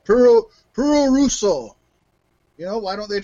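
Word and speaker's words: puroresu: And it's spelled like P-U-R-O-S-E-R-R-O. Puro Russo is what it was called. It came about because that was how the Japanese pronounced pro wrestling when they came over to America. They couldn't say pro wrestling. They said, Puro Russo.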